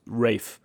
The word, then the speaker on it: Raiff: So the number one thing there is Cooper Rafe. I don't know how you pronounce his surname. It's Rafe, R-A-I-F-F.